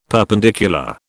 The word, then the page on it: perpendicular: British English